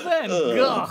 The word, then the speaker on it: van Gogh: I had an art teacher who basically the whole time was like, it's pronounced Van Gogh. And made us all pronounce that. And like, being, you know, a boy in my 20s, I was the worst.